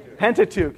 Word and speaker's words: Pentateuch: Let's say that, Pentateuch, say that loud. Pentateuch, it's spelled P-E-N-T-A-C-H-E-U-C-H.